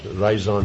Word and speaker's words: raison: Raison d'être? I don't know how you say it. The reason you're doing something. How do you say reason? – Raison. – Raison. – Ah, yes, in French. La raison d'être.